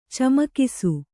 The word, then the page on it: ಚಮಕಿಸು: camakisu